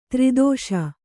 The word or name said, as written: ದುರ್ದೆಶೆ